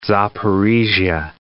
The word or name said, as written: Zaporizhzhya